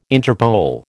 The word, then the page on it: Interpol: American English